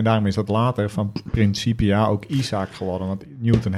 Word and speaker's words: Principia: Waarom zeg je nou Principia? Ja, dat is eh, de Latijnse uitspraak. Sorry, Principia, sorry.